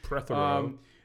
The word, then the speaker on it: Prothero: Prothero, I want to say I pronounce his name right, maybe. – Prothero.